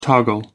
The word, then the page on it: toggle: American English